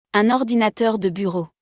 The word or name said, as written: Un ordinateur de bureau